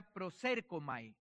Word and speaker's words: proserkomai: La palabra acerquémonos viene de una palabra griega que se pronuncia proserkomai. Proserkomai.